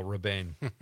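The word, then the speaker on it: Rebane: I think it's Bill Reban. Oh, there it is. Reban. Is that Ray? That would be Ray-Ban. – Rabane? – Right? Rabane. – Rabane? – That sounds more directorial, Rabane.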